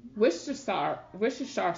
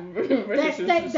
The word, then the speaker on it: Worcestershire: And then she doused it with some Worcestershire sauce. I'm not saying it right. Yeah. – Worcestershire steak sauce.